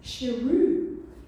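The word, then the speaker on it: Saroo: And he'd been calling himself Saru. And his mum said, your name's not Saru, it's Shiru. which means lion.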